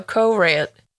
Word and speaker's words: Courant: C-A-U. C-O-U. – C-O-U. – Spelling lessons. – Current. – C-O-U-R-A-N-T. All right. So Corant.